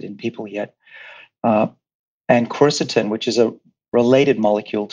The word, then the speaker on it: quercetin: Um, Q U E R C E T I N, quercetin.